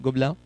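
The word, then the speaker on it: Gobelins: Fue egresado de la Universidad de la Imagen de Gobelin, se pronuncia en español, Gobelin, y fue reclutado por, en Francia, en París, fue reclutado por Amblimation de Spielberg para trabajar como animador.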